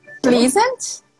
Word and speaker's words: pleasant: Pleasant. – This one we say pleasant. Pleasant.